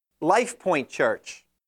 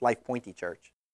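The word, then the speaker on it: Life Pointe: The Strong Tower Church, the Next Generation Church, the Unlimited Life Church, the Outreach for Souls Church, Life Point Church, with the silent E, so it's Life Pointy Church, the Jesus Church, the Excellence Church, the Sportsman's Church, Cowboy Fellowship, the Truth for Today Christian Church, the Prevailing Faith Church.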